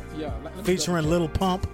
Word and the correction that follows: Lil Pump: Or my bad, Lil Pump, because everything is spelled L I L.